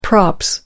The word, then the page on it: props: British English